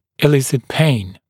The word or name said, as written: elicit pain